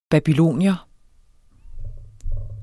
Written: babylonier

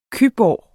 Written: cyborg

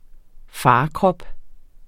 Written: farkrop

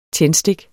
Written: tændstik

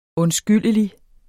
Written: undskyldelig

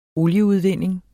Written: olieudvinding

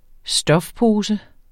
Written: stofpose

